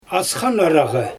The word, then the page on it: asxan aragy: Xyzyl